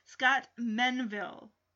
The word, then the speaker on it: Menville: So Scott Melville, happy Menville. Guys, I'm sorry. I feel terrible now. I just mispronounced his name. Scott Menville.